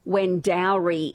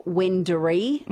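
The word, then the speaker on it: Wendouree: A text message says people say Wendowry instead of Wendery. Wendowry, because you've got the O-U-R-E-E, so it does seem like it might be Wendowry instead of Wendery.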